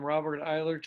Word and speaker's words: Robert Eyler: And then I'm going to welcome Robert Eyler today.